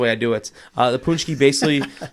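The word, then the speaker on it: pączki: What's the story behind the punchki or is it punchki? How to say it? – We say punchki. – Punchki. – Yeah, some people say patchki.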